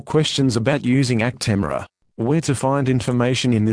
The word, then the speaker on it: Actemra: This is the consumer medicine information for Actemra and Actemra SC pre-filled pen, Actpen, pronounced Actemra.